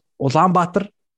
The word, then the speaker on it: Ulaanbaatar: It's pronounced Ulaanbaatar.